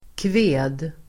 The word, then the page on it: kved: [kve:d]